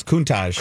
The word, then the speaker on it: Countach: The Lamborghini Countach. Is that how I say it? – No, it's Countach. – Countach, the Wolf of Wall Street car, is up for auction.